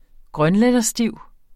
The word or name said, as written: grønlænderstiv